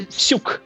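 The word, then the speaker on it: Psiuk: Uh, uh, How do you say it? I want to. Psuk. It's psuk. It's just psuk. Oleg. Oh. Oleg? Oleg, yes, right. Perfect. And uh, the surname is Psuk.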